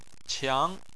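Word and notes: qiang: tone 2

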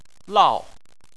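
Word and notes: lao: tone 4

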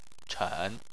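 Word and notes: chen3: tone 3

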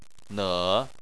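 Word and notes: ne: tone 3

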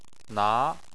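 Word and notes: na: tone 2

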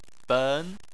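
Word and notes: ben: tone 2

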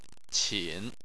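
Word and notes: qin: tone 3

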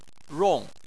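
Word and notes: rong: tone 4

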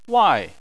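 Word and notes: wai: tone 4

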